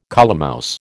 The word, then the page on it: colemouse: American English